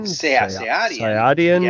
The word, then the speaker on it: Sayadian: It's a pornographic science fiction film designed and directed by Stephen, how do you say it? – Sayadian? – Sayadian. Yeah, yep.